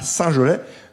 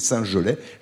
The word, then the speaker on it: Saint-Gelais: Merci d'être avec nous, merci de nous avoir rejoints à Saint-Gelais. Gelais, ça s'écrit G-E-L-A-I-S, je ne l'ai pas précisé, on me demande souvent d'épeler, donc c'est Saint-Gelais, G-E-L-A-I-S, commune de 2200 habitants.